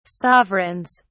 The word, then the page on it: sovereigns: British English